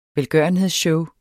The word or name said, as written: velgørenhedsshow